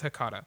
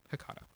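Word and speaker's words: H'catha: It's Hakata. It's H apostrophe C-A-T-H-A. Hakata.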